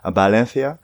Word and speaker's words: Valencia: Man sagt ja Valencia, also das V spricht man als leichtes B aus. Mhm. Valencia.